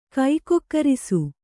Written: ಗುಗ್ಗುರಿಸು

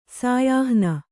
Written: ಸಾಯಾಹ್ನ

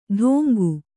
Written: ಡೋಂಗು

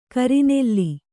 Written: ಕರ್ನಲ್ಲು

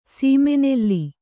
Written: ಸೀಮೆನೆಲ್ಲಿ

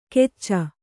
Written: ಕೆಚ್ಚ

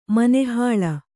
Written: ಮನೆಹಾಳು

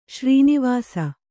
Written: ಶ್ರೀನಿವಾಸ

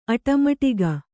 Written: ಅಟಮಟಿಗ

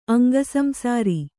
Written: ಅಂಗಸಂಸಾರಿ

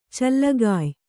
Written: ಚಲ್ಲಗಾಯ್

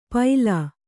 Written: ಪೈಲಾ

